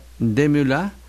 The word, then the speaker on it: Desmoulins: A ver, decimos Maximiliano, Robespierre, pronunciando Robespierre, como en francés, pero a Desmoulins se escribe Desmoulins, se pronuncia Desmoulins, si lo llamamos Camille, no Camilo.